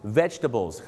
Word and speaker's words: vegetables: vegetables ครับผม vegetables เขาจะไม่ออกเสียงตัว jet ครับดังนั้น